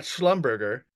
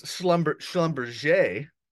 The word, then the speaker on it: Schlumberger: It's not Schlumberger, it's Schlumberger.